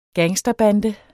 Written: gangsterbande

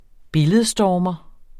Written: billedstormer

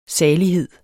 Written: salighed

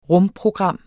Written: rumprogram

